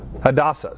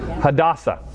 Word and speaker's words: Hadassah: And Mordecai had brought up Hasadiah, that is Esther. And I said that wrong. How do you say that? – Hadassah.